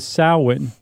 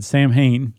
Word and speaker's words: Samhain: And in particular, there was Samhain, which forever I've always said Samhain because that's how it's spelled. – No, you said Samhain right when we did our Halloween episode, didn't you? – Probably.